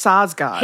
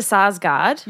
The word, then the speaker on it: Sarsgaard: Well, they're two different names. – Skarsgård. – Yeah, I think it's Sarsgaard. – He is a Sarsgaard. – Yeah. – Alexander is a Skarsgård.